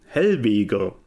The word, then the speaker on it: Helvegr: Hellwege, also H-E-L-V-E-G-R. Hellweger.